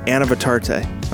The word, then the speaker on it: Anavitarte: I just need to know how to pronounce it. Anavitarte. Sweet. All right. You ready for us? Well, I'm glad to have my good friend Erskin Anavitarte. Okay, let me say that again.